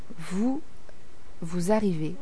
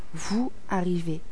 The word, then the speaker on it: vous arrivez: Comme vous arrivez, c'est le son Z, le même son que celui de l'abeille. Z, vous arrivez.